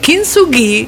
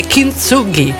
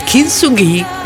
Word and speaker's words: Kintsugi: La pronuncia giapponese, ve la dico perché ho studiato, come si dice, è Kintsugi, invece se lo vogliamo pronunciare all'inglese è Kintsugi. Quindi c'è una differenza praticamente di accento, però in giapponese è Kintsugi, ecco, con l'accento finale.